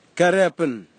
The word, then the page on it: kareapin: kwamera; Tanna